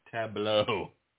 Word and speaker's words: Tableau: Tableau. – Really? Sounds like a David and John's concoction. – Well, it's spelled French style, so it's like tableau.